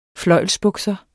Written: fløjlsbukser

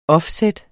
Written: offset

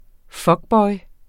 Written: fuckboy

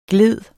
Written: glider

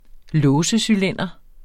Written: låsecylinder

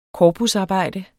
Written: korpusarbejde

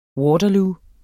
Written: Waterloo

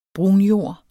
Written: brunjord

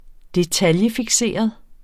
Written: detaljefikseret

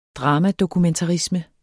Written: dramadokumentarisme

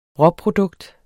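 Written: RÅPRODUKT

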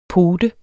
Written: Poten

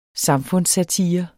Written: samfundssatire

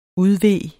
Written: udve

